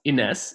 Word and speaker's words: Ines: Um, thank you so much for your time today, um, Inish. How, how do you say Inish to to English speaking people? In, is it? Um, actually, I go with Ines. – Ines. Usually. There you go.